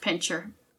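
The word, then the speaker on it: pinscher: I think it's got the S in there, but I think it's pronounced pincher.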